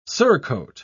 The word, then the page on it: surcoat: British English